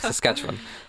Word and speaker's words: Saskatchewan: British Columbia and Saskatchewan. Ugh, I butchered the pronunciation. Wait. – I can't. – Oh, like, is that in Chapel Roan song? – Oh, probably. – Saskatchewan. – Yes, Saskatchewan.